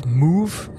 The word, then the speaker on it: Moev: So I'm going to talk to you about Moev, or I'm thinking I'm pronouncing it Move. It's a new band. It's a band from Canada, and they founded in 1981. And in an interview, I read where the name comes from. So it's spelled M-O-E-V, by the way. – Mm-hmm. – It's a color, and the color is Mauve. It's M-A-U-V-E.